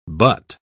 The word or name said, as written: but